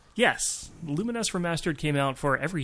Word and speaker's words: Lumines: Lumines Remastered, and I've confirmed that's how you're supposed to pronounce that. Ever since it came out in like what 2006, I called it Lumines, but it's Lumines apparently.